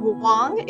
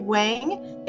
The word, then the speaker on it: Wang: Hi, my name is Andrea Wang, and you can pronounce that Wang if you want to. It's Wang in Mandarin, and I just say Wang in American English so that everybody spells it with an A and not an O. But no matter how you say it, it's not incorrect.